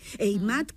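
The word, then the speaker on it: Heimat: Eimat, que se escribe con H, Eimat Atlántica, sí.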